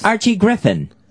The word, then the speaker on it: Griffin: People writing in that you said Archie Griffith. How did you say it? – Archie Griffin. – All right. So you got it.